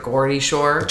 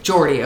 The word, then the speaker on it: Geordie Shore: It's either Geordie or Gordy Shore. – Geordie, okay.